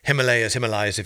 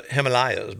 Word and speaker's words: Himalayas: And he's like, well, you know, we pronounce it, you know, as Brits, we pronounce it Himalayas, but you guys say Himalayas, so I just wanted to get both.